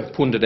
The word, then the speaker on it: pundit: There would be no pundit out there at the moment. – That's interesting because that word for a political expert and commentator, according to the Macquarie Dictionary, is pronounced like this. – Pundit.